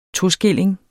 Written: toskilling

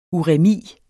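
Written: URÆMI

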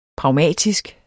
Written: pragmatisk

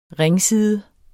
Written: ringside

